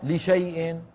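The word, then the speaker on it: لشايء: لام شين ألف ياء، كيف تقرأ؟ لشيء. هل أثرت هذه الألف في النطق؟ لم تؤثر.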